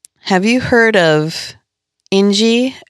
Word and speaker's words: Inji: So it's spelled I-N-J-I. Ingie. – Okay, Ingie. Okay.